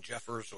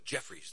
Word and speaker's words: Jeffries: I'm not too familiar with Don Jeffers or it's Jeffries. I know. Obviously, Eric, you're not familiar with the Don Jeffers. That's Jeffries. That's you wrote Jeffers, J-E-F-F-E-R-S. That's fine. That's fine. And you won't get his name wrong once you hear him. I can guarantee you damn that. I can guarantee you damn that. Jeffries, that's J-E-F-F-R-I-E-S, Don Jeffries, or Bruno.